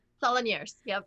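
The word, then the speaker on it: Salonnières: did I say that right? – Salonieres, yep.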